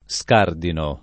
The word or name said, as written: scardinare